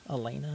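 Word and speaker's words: Alaina: One Beer by Hardy, Lauren Alana. – Elena. – Elena, something like that.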